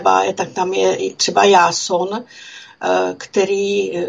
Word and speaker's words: Jáson: a taky se nevyslovuje jako s tím hebrejským způsobem, ale vyslovuje se jako Jásun, píše se I-O, jo, jako tím, tou výslovností tak, jak je, tak to I-O se čte jako já, tak jako se třeba v Byzancii a podobně tímto způsobem se mluvilo, to jsou poloslovanský, slovanský řeči.